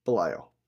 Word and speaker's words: Belial: So we start off with the ending of the first one, which is Dwayne hanging from the hotel sign with his brother, Belial. Am I saying that right? – Yeah, Belial.